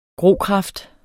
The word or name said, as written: grokraft